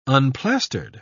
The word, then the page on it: unplastered: British English